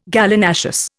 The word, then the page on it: Gallinaceous: Indian